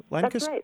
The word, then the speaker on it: Lancaster: Lancaster.